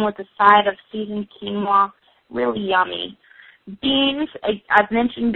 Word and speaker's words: quinoa: It's quinoa, and that is spelled Q-U-I-N-O-A, quinoa.